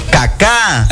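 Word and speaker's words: Cacá: Caca. – Eso. Pero así se. No, caca. – Ah, bueno. – Con acento en la última A.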